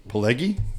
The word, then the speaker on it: Pileggi: There's How no did you say his name? Mitch Pileggi. Pileggi. – Yeah, that sounds right.